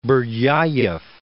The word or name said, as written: Berdyayev